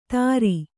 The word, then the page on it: ತರಿ: kannada